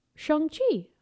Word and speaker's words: Shang-Chi: And then the last thing I have on Marvel movie news is Shang-Chi.